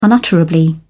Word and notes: unutterably: British English

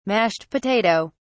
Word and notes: mashed potato: British English

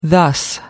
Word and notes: thus: American English

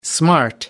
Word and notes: smart: American English

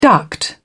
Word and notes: duct: British English

